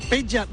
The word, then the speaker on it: Predrag: Ya saben nuestros oyentes, Peja Rajkovic, porque se escribe Predrag, pero me han dicho que es como Peja en Villancho.